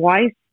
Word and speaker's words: Weiss: Yeah, you can find me on Instagram as my name, Jasmine Weiss, W-E-I-S-S is how you spell it.